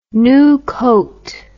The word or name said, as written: Nukote